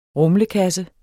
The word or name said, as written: rumlekasse